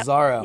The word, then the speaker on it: Szaro: Szaro.